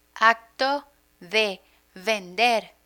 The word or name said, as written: acto de vender